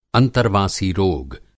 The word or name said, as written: अंतर्वासी रोगी